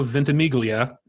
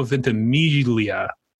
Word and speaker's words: Ventimiglia: It is not Milo Ventimiglia and Joe Latruglio. It is Milo Ventimiglia and Joe Latruglio. It's like a soft / silent G.